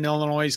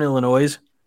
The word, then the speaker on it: Illinois: Did you just say Northern Illinois? – Yeah. – I don't know if you know this, but the S is silent. – No, not in my house, it isn't.